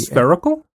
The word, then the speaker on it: spherical: The S-P-H-E-R-I-C... – Spherical? – Huh. – What do you say? – Spherical. – What did I say? Spherical? – Spherical. – Is it spherical? – Rhyming with clerical.